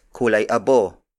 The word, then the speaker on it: kulay abo: Another variation is kulay abo.